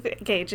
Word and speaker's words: Gage: So I would go Google Phineas Fage, Gage if you're not familiar with it.